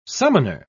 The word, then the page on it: summoner: British English